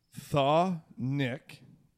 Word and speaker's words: chthonic: Thaw-nick is how it's pronounced.